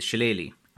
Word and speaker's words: shillelagh: How I would usually say it is Shillelagh. And I think that's the most common way that I hear it pronounced now.